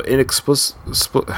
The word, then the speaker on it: inexplicably: So inexplicity, uh, uh, sorry, so inexplicably, how do you say that word? – Inexplicably.